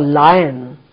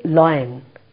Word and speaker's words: lion: L for lion. This is another mistake that most people make. They say loin. No, loin is the wrong pronunciation. Lion is the correct pronunciation.